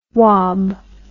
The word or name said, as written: wob